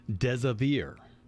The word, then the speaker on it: desservir: And now, forgive me if I mispronounce this, but I believe it's desservir.